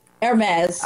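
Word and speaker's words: Hermès: Hey, you out of the whole panel know how to say Hermes. Is that how you say the scarf? You've told me 50 times. But Hermes. – Oh, okay. Hermes.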